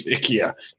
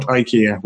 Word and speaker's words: IKEA: Although, as the adverts keep telling me, I do pronounce IKEA wrong as IKEA. – Oh, IKEA? As the Swedish gentleman on the adverts tells me, it's IKEA. It is not IKEA. We all say it wrong.